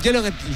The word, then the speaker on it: Gelleretli: Ah, un Geller Etli. – Voilà, qui s'écrit G-E-L-L-E-R-E-T-L-I.